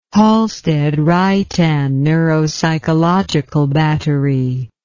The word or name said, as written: halstead reitan neuropsychological battery